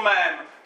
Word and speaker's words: mém: Každý asi zná pojem mém, já jenom vysvětlím, proč to píšu s čárkou, protože Richard Dawkins, jo, tohle je překlad český, kde mělo by být vyslovováno tak, aby se rýmovalo se slovem gen. A to je překlad, který mě strašně rozčiluje, protože v originále je it should be pronounced to rhyme with cream. Jo, takže mělo by se vyslovovat tak, aby se rýmovalo se slovem krém. Jo, takže proto mém.